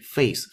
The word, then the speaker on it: phase: 视集团的一个发狂了的、令人感到很鲁莽的 pace 步速步履 of expansion 扩张的步速步履，and move the company toward a more moderate phase of growth，并且呢要把这个公司引到什么方向上去？更加温和的一个生长的、成长的阶段，phase。 P H A S E phase 非常重要，不能读成别的音，它是一个以 f 这个音开头的音叫 face，因为 P H 字母组合在单词的最前面出现，一般都读 ph 那个 f 那个音，对吧？